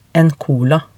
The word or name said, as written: en cola